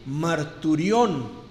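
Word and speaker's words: marturión (μαρτύριον): Testimonio viene de una palabra en griego que se pronunciaría más o menos así: marturión. Testimonio.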